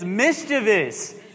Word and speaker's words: mischievous: All right, this is how you say it. It's mischievous. If there's no other I behind the V.